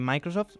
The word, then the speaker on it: Microsoft: Sí, eso es lo que propone este magnate multimillonario y cofundador de Microsoft. ¿Lo he dicho bien? – Muy bien, muy bien.